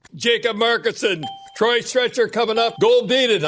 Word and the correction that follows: Goldobin: I don't know how to pronounce that name pretty good. – Goldobin, yeah.